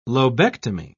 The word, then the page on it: lobectomy: British English